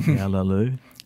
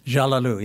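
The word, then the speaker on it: Cağaloğlu: McClatchy, in the August 15th, 2011 issue of The New Yorker, we published your poem, Yalalu, that's C-A-G-A-L-O-G-L-U, pronounced, roughly speaking, Yalalu. – Jalalu, yes. – Or Jalalu. I'm almost there.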